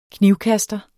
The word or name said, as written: knivkaster